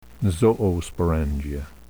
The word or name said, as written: zoosporangia